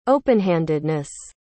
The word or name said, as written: openhandedness